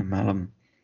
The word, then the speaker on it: Malum: Oh yeah, Malum. How do you spell it again? M-A-L-U-M. – Yeah, like you have someone called Malcolm who shortens his name but forgets his surname.